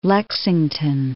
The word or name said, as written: Lexington